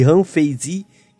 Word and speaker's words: Han Feizi: Munzi e Han Feizi, que eu não sei pronunciar, então se eu tá pronunciando errado, me desculpem.